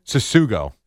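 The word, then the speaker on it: Tsutsugo: How do you say his last name? – Susugo. – Susugo.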